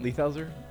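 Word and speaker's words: Leithauser: This also reminds me of Hamilton Lighthouser. Is that how you say his name? – Hamilton... – Lighthouser? – Yeah.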